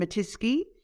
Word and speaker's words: Matisky: It's Catherine Matisky. And the easiest way to remember it is that it rhymes with whiskey.